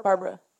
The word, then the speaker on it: Barbra: Barbra. But it's B-A-R-B-R-A. There's no A between the last B and the extra A.